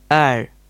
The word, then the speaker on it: heir: The next pair are H-E-R-E, here, H-A-R-E, here. They are different. – The next pair, H-A-I-R, hair, and H-E-I-R, air, sound different. – Yes, you are correct. The first word, H-A-I-R, is pronounced as hair. And the second word, H-E-I-R, is pronounced as air. So they sound different. – The next pair are B-E-E-R, beer, B-E-A-R, beer.